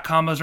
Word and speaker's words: Combos: But, yeah, Combos rhymes with shows.